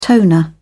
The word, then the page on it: toner: British English